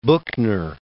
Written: Buchner

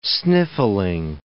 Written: sniffling

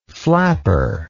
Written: flapperism

